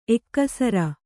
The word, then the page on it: ಎಕ್ಕಸರ: ekkasara